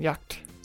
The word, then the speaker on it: jagt: Altså for eksempel det allerfedeste, du kan købe, det er en jagt. Hvordan udtaler man det? En jagt? – En jagt. En jagt. En jagt. – Ja, svært ord.